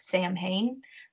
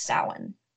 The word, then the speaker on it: Samhain: It sounds like Samhain, but it's actually pronounced Samhain.